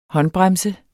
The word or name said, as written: håndbremse